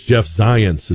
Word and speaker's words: Zients: I think it's Jeff Zients is how you pronounce his name, with a Z, but it sounds like science.